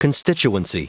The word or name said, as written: constituency